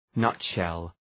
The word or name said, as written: nutshell